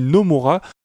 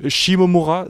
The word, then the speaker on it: Shimomura: Et si vous êtes comme moi et qui, qui avez prononcé pendant très longtemps Yokoshi Nomura, vous inquiétez pas, j'étais aussi un idiot, mais on peut se corriger, c'est tout à fait possible.